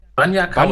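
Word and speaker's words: Bagna Cauda: Es gibt es wirklich und auf der Wikipedia-Seite von Bagner, wie wird das ausgesprochen? – Banya Kauda. Banya Kauda ist sogar Babylon 5 verlinkt als popkultureller Einfluss der, dieses italienischen Gerichtes.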